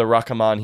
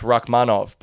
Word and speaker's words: Rakhmonov: He has been absolute fed to the Rakhmon here, which is actually how I used to pronounce Rakhmonov before I heard someone say it correctly.